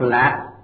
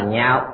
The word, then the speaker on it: nhạo: Chữ này đọc giọng cao, không đọc lạ mà đọc là nhạo.